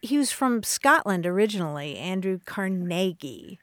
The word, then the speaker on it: Carnegie: Carnegie. – Accent on the second syllable. – With more of an A than an E.